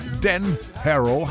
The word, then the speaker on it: Dan Harrow: Ein Titel, Dan Harrow, habe ich das richtig ausgesprochen? Ja.